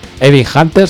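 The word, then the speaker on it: Evil Hunter: Pues voy a deletrearlo y la gente que lo busque. E, una V, una I, una L, y luego separado, una H, una U, una N, una T, una E y una R. Evin Hunter se pronuncia.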